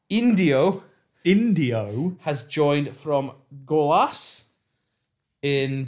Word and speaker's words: Indio: I, with that long I, Indio.